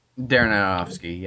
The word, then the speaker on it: Aronofsky: It's directed by Aronofsky. I think that's how you say it, right? – Darren Aronofsky, yeah.